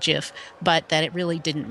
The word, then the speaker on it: Jif: So the way that I pronounce it is Jif, as with a J and a soft.